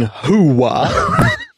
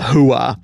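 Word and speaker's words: hooah: Though he's saying it wrong, he places far too much of a W sound in the second syllable. The real version is closer to hoo-ah.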